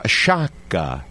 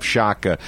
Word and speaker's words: Sciacca: They came over in a boat from Sciacca.